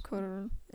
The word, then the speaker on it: Cuarón: For Cold War, uh, Yorgos Lanthimos for The Favorite, Alfonso Cuarón for Roma, uh, Adam McKay for Vice. I, of course, said Alfonso Cuarón. Am I even saying that right? Yeah, Cuarón.